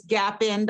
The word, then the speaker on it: Gapin: It's Gapin, Dr. Gapin. Yeah. I said it incorrectly earlier, so I wanted to correct that.